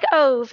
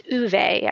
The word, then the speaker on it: Ove: I've probably read this 5 months ago or so, but it was a man called, it looks like Ove, it's O-V-E, but it's pronounced Uwe, I think.